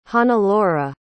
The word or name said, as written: hannelore